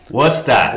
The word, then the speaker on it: Worcester: Finally made it to, how do you say it? Worcester? – Worcester. – Worcester. All right. – No R's.